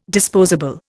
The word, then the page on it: disposable: Indian